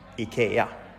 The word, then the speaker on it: IKEA: I call it IKEA, but what's the correct pronunciation? – IKEA.